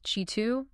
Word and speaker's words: Qi2: It's technically pronounced Qi2, but yes, it is a revolution.